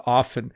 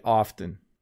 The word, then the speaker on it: often: So when I speak slowly and clearly, I say often. So often when I want to make a point, I will pronounce the T in the word often. But when I speak really quickly, often when I'm speaking really quickly, you don't hear the T.